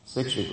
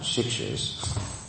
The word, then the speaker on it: siksha: siksha gurus, not shikshas, the siksha gurus. There's a difference in the pronunciation. Actually, in Hebrew, shiksha means a lady who is not Jewish. And siksha means to give instruction.